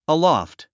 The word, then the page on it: aloft: əlɔ́ft; アロフト